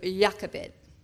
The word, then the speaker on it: Jochebed: Well, we pronounce it in the Hebrew, Jochebed.